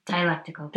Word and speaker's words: dialectical: If I can just get you to weigh in here, the first one uh, that I wanted to ask you about was dialectical. Is that how I say that right? Dialectical. Thank you.